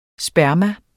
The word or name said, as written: sperm